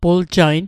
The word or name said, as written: puljain